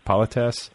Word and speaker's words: politesse: And then, you know, but the thing about it is that, like, yeah, there is that like level of politesse, or is that the way to pronounce it? Politesse? – Politesse, yeah.